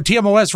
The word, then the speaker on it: TMOS: And no, TMOS rhymes with 20% less.